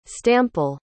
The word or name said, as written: stample